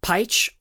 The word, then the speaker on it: Pietsch: This one goes out to Travis Peach, or Peach on the grams. Let me spell that for you. That's Travis, T-R-A-V-I-S, Peach or Peach, P-I-E, T-S-C-H.